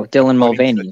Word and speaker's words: Dylan Mulvaney: Oh, Dylan Mulvaney.